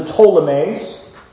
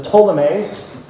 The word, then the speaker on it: Ptolemies: Well, you have the south and the Ptolemies. Boys and girls, I know it starts with a P, but the P is silent. The Ptolemies and the north, the Seleucids, with a whole bunch of Seleucuses and Antiochuses.